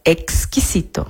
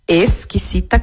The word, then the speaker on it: exquisito: Presta atenção na pronúncia, exquisito. Mas cuidado, se você disser que a comida está exquisita, exquisita com S, o garçom vai achar que o prato está estranho ou ruim.